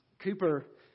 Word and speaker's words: Cowper: William Cooper, his last name is spelled C-O-W-P-E-R, but it's pronounced Cooper.